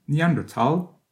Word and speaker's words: Neanderthal: la forme ancienne Neanderthal est encore très répandue avec le H, entre le T et le A de Thal, ce qui peut induire, pour la séquence Thal, une prononciation incorrecte du nom allemand originel.